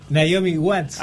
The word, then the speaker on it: Naomi Watts: Naomi Watts, ahí como está. 25 watts, ¿no? Como los watts de las bombitas.